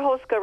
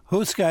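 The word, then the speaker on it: houska: Hoska recipe's probably been in the family for around three generations.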